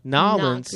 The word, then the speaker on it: New Orleans: New Orleans. Nolens. Not Green Bay. – Gotta say it right. Nolens.